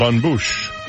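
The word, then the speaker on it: bonne bouche: B-O-N, you know French, B-O-N-N-E, Bouche. B-O-U-C-H-E. Bon yeah, Bouche. – Bon Bouche.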